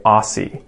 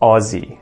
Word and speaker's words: Aussie: And Americans always say this incorrectly. They always say Aussie and not Aussie, okay? Aussie. Aussie or Oz.